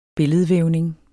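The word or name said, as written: billedvævning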